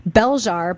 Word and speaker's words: Belzhar: Bell Jar by Meg Wolitzer, which is spelled B-E-L-Z-H-A-R, is, I think it's Wolitzer's first young adult novel, and it is so awesome.